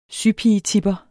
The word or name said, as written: sypigetipper